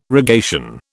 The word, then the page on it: rogation: British English